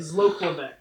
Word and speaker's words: Zloklavec: Father Zoglavic. – Okay. – Did I pronounce it correctly? – Yeah, Zloklavec. Okay, yeah. I misplaced the L.